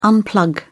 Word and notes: unplug: British English

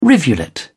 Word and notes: rivulet: British English

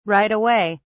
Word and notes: right away: British English

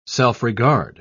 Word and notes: self-regard: British English

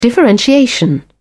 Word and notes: differentiation: British English

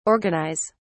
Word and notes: organise: British English

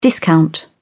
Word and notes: discount: British English

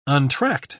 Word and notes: untracked: British English

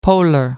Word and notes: polar: American English

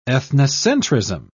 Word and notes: ethnocentrism: British English

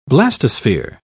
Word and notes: blastosphere: British English

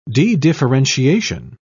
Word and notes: dedifferentiation: British English

